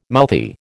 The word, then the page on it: mouthy: American English